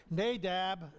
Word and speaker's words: Nadab: I had that guy look it up on the internet and pronounce it, so I got it correct. Nadab and Abihu, they offered a strange sacrifice.